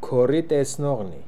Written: Koritesnokhnian

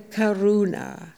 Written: karoona

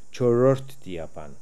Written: Chorortdiapan

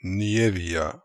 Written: Nievia

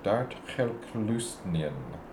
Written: Dartkhelklustnian

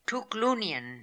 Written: Tookloonian

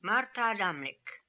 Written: Martaadamlik

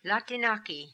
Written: Latinaki

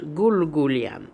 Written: Goolgoolian

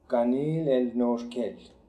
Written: Kanil El Norkel

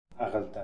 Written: Akhaldan

